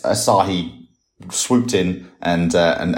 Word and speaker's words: Asahi: Asahi.